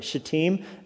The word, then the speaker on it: Shittim: You have to be really careful pronouncing that word, by the way. Shittim, S-H-I-T-T-I-M. It's like Satine from Wreck-It Ralph. Shittim. Because you could easily cuss and not even mean to.